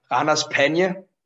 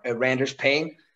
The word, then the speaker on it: Ranas Pena: The tag team I wrestle in without a mask is called Ranas Pena. On English, when the commentators pronounce it, it's called Randers Pain.